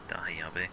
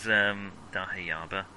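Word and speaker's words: dahabeah: I was going to send you a floor plan of a Dahayabe, but it is Dahayaba.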